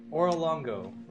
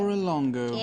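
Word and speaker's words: Orolunga: There is going to be a city called Oralongo right there. – Wait. Oralongo.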